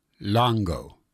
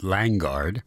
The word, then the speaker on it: Langgaard: As I said, a lovely piece of music with a very poetic name, Unnoticed Morning Stars, by Rue Langgaard. The last name spelled L-A-N-G-G-A-A-R-D. One might be tempted to pronounce it Langard, but I'm told it's Langgaard.